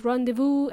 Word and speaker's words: rendezvous: Rendezvous is pronounced rendezvous and it's spelled with difficulty, okay? R-E-N-D-E-Z-V-O-U-S. I think it's a French word, but it's pronounced rendezvous, okay?